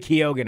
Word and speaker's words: Keoghan: Barry Keoghan.